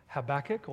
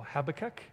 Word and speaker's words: Habakkuk: Uh, the other prophets at the time of uh, Josiah were Habakkuk or Habakkuk, however you like to pronounce his name, and also Zephaniah.